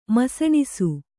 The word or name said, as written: ಮಿಸುನಿಸು